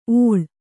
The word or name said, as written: ಓಳ್